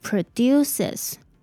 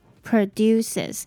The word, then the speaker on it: produces: 那因为这个字有加上 s，当它是 e 结尾的时候，这里的 e s 要念作 is，所以这个字念作 produces produces。那记得要把重音放在第二个音节哦。